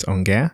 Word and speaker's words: Ongea: So you spell it Ongea, which is how you pronounce Ongea.